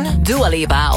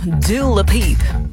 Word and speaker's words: Dua Lipa: Dua Lipa of Dua Lipiep. Zoals een Amerikaanse radio-dj ooit zei. Ze kregen het niet uitgesproken, dus ze maakten altijd Dua Lipiep.